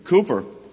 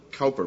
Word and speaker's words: Cowper: And then if you know something of the biography of William Cowper, I always mistakenly pronounced it Cowper, but it's Cowper, you know it adds to the poignancy and power of that wonderful hymn, God Moving in a Mysterious Way.